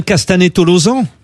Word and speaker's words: Castanet-Tolosan: On prononce bien Castanet-Tolosan ? – C'est ça. – Ouais, oui.